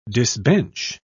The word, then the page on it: disbench: British English